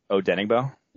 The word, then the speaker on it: Odenigbo: Odenigbo. – Yes, that guy. And then interior offensive line. Like, if they're trotting out Elfline, I would be really worried. – Oh, I remember Odenigbo from back from his recruiting days.